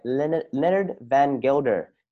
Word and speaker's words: Leonard van Gelder: Today, I'm joined by Leonard Van Gelder.